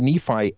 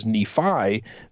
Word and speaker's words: Nephi: Now, we as 21st century Americans and, of course, 20th century Mormons have pronounced this as Nephi, but sometimes the I on the end of Nephi can be pronounced as an E, as a long E.